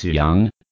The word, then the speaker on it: Young: S-T-E-F-A-N-I-E. – And what's your family name? – My family name's Young. – Okay, Stefani. Here's your student card. – Thank you. Oh, my family name's not correct. – Oh, sorry. How do you spell it? – It's Young. Y-O-U-N-G. – Y-O-U-N-G? – That's right.